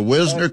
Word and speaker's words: Wisner: And the Wisner kid from Texas, he was the – am I saying that right? W-I-S-E – how are you – Wisner. – That's right.